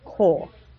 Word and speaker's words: kol: That's really frustrating for someone who sees the word kol in the book marked kaf, kamatz underneath it, and lamed, and it would normally be pronounced kol, but because it's a kamatz katan, which is ironic because it is bigger when it's usually marked, kamatz katan is pronounced kol. For